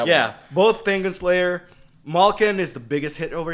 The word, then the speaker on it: Malkin: Malkin.